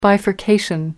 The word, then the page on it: bifurcation: American English